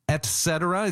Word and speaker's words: et cetera: Et cetera. It's pronounced et cetera, not et cetera. The T comes before the C for some reason.